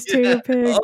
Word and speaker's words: TTRPG: Yeah, Tater Pig actually began as on Twitter, I saw, you know, another person who's, you know, pronouncing TTRPG as Tater Pig. – Oh! – Oh my gosh! – Oh, I didn't even get that! – TTRPG is Tater Pig.